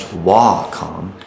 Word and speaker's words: Wacom: And by the way, it is pronounced Wacom.